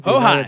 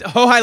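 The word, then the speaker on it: Houhai: I performed the Huhai Lake in Beijing. – Hohai. – Area. Yeah. – Yeah. – And you just corrected my pronunciation in Chinese, which I don't give a shit. So, I'm joking. – It's Hohai Lake.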